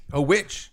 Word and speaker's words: Serafinowicz: I believe we all say Peter Serafinowicz, and I think it's Witch. – Witch.